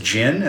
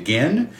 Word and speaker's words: Ginn: There was a point at which also he mispronounced Pettibon's given last name as Jin as opposed to Gin.